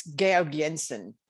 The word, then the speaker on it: Georg Jensen: If you were in Denmark, it's Georg Jensen, and that's probably not said the right way either.